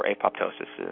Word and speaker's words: apoptosis: We're turning on something called apoptosis or apoptosis is how it's pronounced sometimes, but you're turning that on.